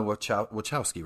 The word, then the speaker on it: Wachowski: And it's directed by Lana Wachowski, right? Is that how they say it? – Yep.